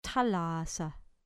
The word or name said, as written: thalassa